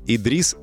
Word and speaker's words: Idris: У нас Идрис и Леус. Правильно я ударение расставил? Все правильно. Да, да, да.